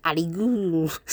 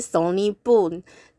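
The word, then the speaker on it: Sonic Boom: 第一个看点就是阿里咕，其实不是阿里咕，它是 Sony Boom，但是这个 Sony Boom 呢，自己人要发音就有点困难吼，所以那个机器有很多音都发不出来的时候，它会变成阿里咕阿里咕阿里咕阿里咕，非常的有趣，所以我就在想阿里咕到底是什么东西啊？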